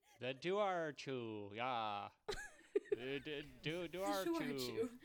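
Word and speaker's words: Dobharchú: So Ireland has this otter/hound hybrid called the Duarchu. Duarchu? I am so sorry. – Formal apology. Ireland. – Ireland. There's a B in there, but the B is not in the pronunciation.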